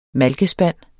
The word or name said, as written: malkespand